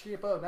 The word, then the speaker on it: Ciampa: That's Ciampa, not Ciampa.